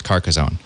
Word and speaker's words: Carcassonne: And it is pronounced Carcassonne.